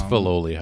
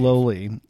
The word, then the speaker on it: Filoli: Or is it Filoli or Fioli? – I never know. I always say it wrong. – It's Filoli house, I believe. – Filoli.